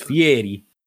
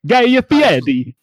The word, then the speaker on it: Fieri: It is Fieri. – Guy Fieri.